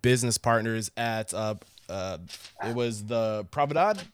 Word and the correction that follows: Pravda: Is that, did I say that correctly? Pravda. Pravda, thank you.